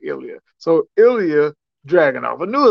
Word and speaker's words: Ilya Dragunov: It was Bron Breakker versus JD McDonough versus, like I said, I can't say his name, but Asia Dravonic. – Ilya Dragunov.